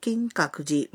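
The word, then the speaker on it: কিং কাকুজি (金閣寺): ধরুন আপনি কিয়োতো নগরীতে অবস্থিত বিখ্যাত কিং কাকুজি মন্দির দেখতে যেতে চান।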